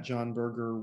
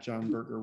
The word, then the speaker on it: Jon Birger: If you want to find me on social media, I'm at Jon Birger 1 on Twitter. My name is spelled a bit oddly. It's J-O-N-B-I-R-G-E-R. So it's at Jon Birger 1 on Twitter.